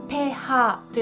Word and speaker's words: pH: この pH っていうのはあペーハーと読むんです